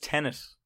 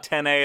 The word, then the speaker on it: Tenet: It's pronounced Tenet. It said it in the trailer. – Tenet? – Tenet. It's not 10A at all.